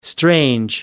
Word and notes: strange: American English

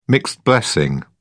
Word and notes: mixed blessing: British English

